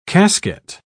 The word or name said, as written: casket